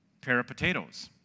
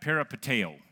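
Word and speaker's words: peripateo (περιπατέω): But when Paul was referring to the Christian's way of living, he used a Greek word which sounds a lot like peripotatoes. Anybody ever remember me saying something like that in the past? There's a Greek word, you pronounce it something like that, peripateo. Not potatoes, but pateo.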